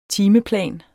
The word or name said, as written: timeplan